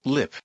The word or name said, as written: lip